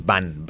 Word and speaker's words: Wann: Es W-A-N-N. – Sí, exacto. – Punto Bandcamp. Punto com. – Exacto. Yo no sé pronunciarlo tampoco, no sé si decir one o one o van o... – Es van, tal y como suena, sí, porque es en alemán, sí.